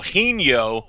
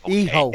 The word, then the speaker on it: Hijo: Hijo. The H is silent, the J sounds like an El Hijo de L.A. Park. That's how it's pronounced.